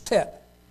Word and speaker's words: teth: The next one is tet. It says T-E-T-H, but I think it's pronounced like tet.